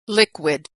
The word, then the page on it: liquid: /w/